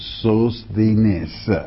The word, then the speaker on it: Sosthenes: Would you please tell me how to pronounce Sosthenes? Well, that's exactly the way you pronounce it, Sosthenes.